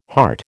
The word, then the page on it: hart: American English